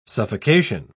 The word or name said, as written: suffocation